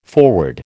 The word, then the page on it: foreword: American English